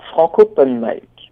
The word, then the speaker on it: Franc'Open Mic: Alors moi, je vais déjà essayer de comprendre le nom de votre de votre concept France Open. – Alors Franco Open Mic. – D'accord, donc déjà je le prononce mal.